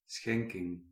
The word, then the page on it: schenking: /ˈsxɛŋ.kɪŋ/